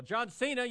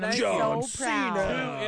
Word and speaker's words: Cena: John Cena, you know who he is. – Yes, and you said his name right again. – John Cena.